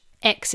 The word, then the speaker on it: Aix: And there's also Coteau d'Aix in Provence, which X is spelled A-I-X.